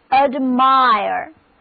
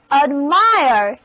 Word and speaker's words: admire: 好了，现在我们再在词根 MIR 之前加上加强语气的 AD 前缀，后面是 E 动词后缀，那么单词 ADMIRE 重音放在第二个音节上，字母组合 IRE 这里读作 IRE。 Now read after me，这是 ADMIRE。 ADMIRE 这个单词字面上的意思就是惊奇不已，那么让我们惊奇不已的事情自然就值得人赞叹钦佩，所以 admire 就是钦佩、羡慕的意思。